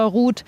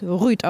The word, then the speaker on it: Ruud: Übrigens muss ich mit dem Stadionsprecher jetzt nochmal hier kurzes Wörtchen austauschen, weil der sagt nach wie vor Ruud und ich habe aus ganz, ganz sicherer norwegischer Quelle, dass es Ruud ausgesprochen wird.